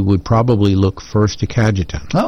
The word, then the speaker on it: Cajetan: Today, the church celebrates the feast of Saint, and you may have to help me out on pronunciation, Catajan? Cajetan. – Cajetan.